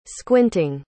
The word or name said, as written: squinting